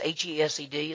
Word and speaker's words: Hesed: It's H E S E D. It's pronounced Hesed, and it means a loyal love, an unchanging love, love that never, never changes.